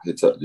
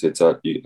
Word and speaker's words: Hitachi: Yeah, Hitachi, just Hitachi.